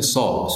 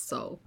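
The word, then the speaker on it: Sool: And I always say that wrong. Did I say that wrong again? – No, it's a Sol, so you haven't said it wrong then. – Sol, yeah, I always say it wrong because it's double O, so I just kind of say Sul all the time. Apologies, it's Sol.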